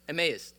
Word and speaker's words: Emmaus: Two of the disciples, not namely the 11, but two of the disciples are walking to, I actually don't know how to say it. I believe it's Emmaus? Emmaus? Emmaus, thank you.